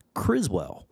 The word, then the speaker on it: Criswell: Mila Nurmi, Vampira, and is narrated by Criswell, which I'm going to acknowledge right now. In the commentary, I say Criswold, okay? I messed up, but I'm owning it.